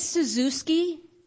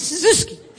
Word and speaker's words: Czyzewski: Miss Suzuki changed my life. At first, her name was the hardest spelling word we had in second grade. C-Z-Y-Z-E-W-S-K-I. And we learned how to pronounce it by sneezing. Miss Suzuki.